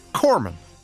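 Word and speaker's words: Corpsman: So it really is pronounced Corman. – That's right. The P and the S are silent. It's derived from the Latin word for body.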